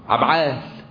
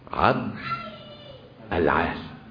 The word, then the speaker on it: عبد العال: ها، عبعال، يا عبعال دي عبد العال، عبد القوي، كل ده غلط.